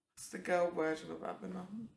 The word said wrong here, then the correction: Abena: Okay, so the male version is Kwabena, the female version is Abena.